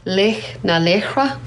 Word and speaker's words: Laoch na Laochra: So you can correct me if I'm pronouncing this wrong. Laoch na Laochra? Is that the... – I would give you 10 out of 10 for that pronunciation.